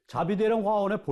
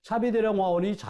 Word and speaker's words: 차비대령: 자비대령 화원의 본래 발음은 차비대령 화원입니다. 그러나 말할 때 된소리를 꺼렸던 조선시대 궁중에서 차비는 자비로 발음되었습니다. 차비대령 화원이 자비대령 화원으로 불리게 된 것은 바로 이 때문입니다. 차비대령 화원 또는 자비대령 화원 어느 쪽으로 불러도 무방합니다.